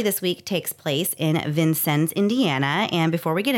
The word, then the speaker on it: Vincennes: So we think we're pretty confident it's Vincennes, but if you Google it, you will get four different pronunciations. So we went to the source, to the people, and they said Vincennes.